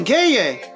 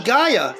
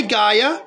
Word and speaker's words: Gaia: If you think Gaia, it's the proper pronunciation, Gaia, not Gaia, but you can say Gaia.